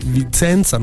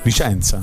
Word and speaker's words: Vicenza: Che tra l'altro Vizenza non si dice. – Vicenza. – È più una roba da Emiliano.